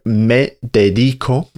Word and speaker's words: me dedico: So me dedico, remember the stress on the I, means I dedicate myself.